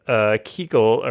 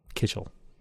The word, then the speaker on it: Kichel: So I'm going to go with Kichel, or Kitchell, K-I-C-H-E-L. Kitchell. – Correct. – Thank you. Okay. Yes. We grew up, we called it Kichlick or Kichel, but yes.